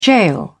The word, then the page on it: gaol: British English